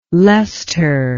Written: Lester

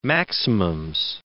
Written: maximums

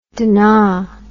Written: dunna

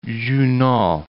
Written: Junot

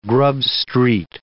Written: Grub Street

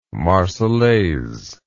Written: Marseillaise

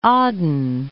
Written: Aden

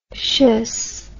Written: tious

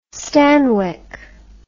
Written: stanwyck

